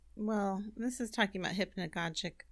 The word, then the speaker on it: hypnagogic: Hypnagogic. – Oh, you pronounced it correctly.